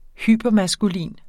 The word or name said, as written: hypermaskulin